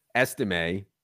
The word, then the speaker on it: Estime: And perhaps that's why they have Audric Estime. I'm sure I butchered that. – Estime at number 6 overall on the big board.